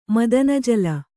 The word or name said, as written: ಮದನಜಲ